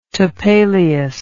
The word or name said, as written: topelius